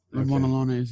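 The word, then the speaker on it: Ramón Allones: Is it Ramon Alones or Ramon Iones? – Talk to JP. – Well, he speaks Spanish and French now? – Well, I mean, it's a Canadian Cuban. – I've always pronounced it Ramon Alones. – Ramon Alones, yeah.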